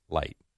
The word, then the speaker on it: Licht: Jason Light, isn't that his name? – Well, it's spelled L-I-C-H-T, Licht. – Light.